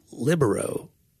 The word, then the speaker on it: libero: Sometimes we hear it called libero, and we think that they're pronounced. It's kind of like you think in the South they don't want to say because it sounds like liberal. It's libero.